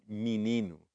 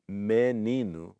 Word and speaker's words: menino: And notice how it comes out menino. So menino, which is written with an E, sounds like it's written with an I.